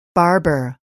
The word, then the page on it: barber: [bɑ́ːrbər]